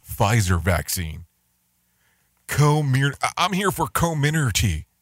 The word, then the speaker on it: Comirnaty: It's pronounced Comirnaty, according to the FDA.